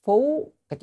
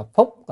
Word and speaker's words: phú: Từ ân phú, cái chữ này đọc là phúc thì không đúng, phất không đúng, phất mà phải đọc là phú.